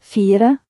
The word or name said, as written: 4